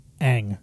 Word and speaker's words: Aang: Aang. – It is Aang. Okay. I watched a lot of, clips and stuff like that, so I'm confused of which is right, but I remember Aang is correct.